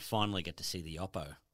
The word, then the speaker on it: Oppo: So 90 gets the Oppo, or come to find out it's pronounced Oppo.